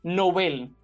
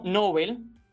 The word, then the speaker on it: Nobel: Hay que insistir en esto que acota el diccionario panhispánico de dudas, es que la palabra Nobel es aguda y así se recomienda pronunciarla en español, como en su lengua de origen, el sueco. Nobel, no Nobel.